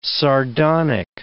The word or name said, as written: sardonic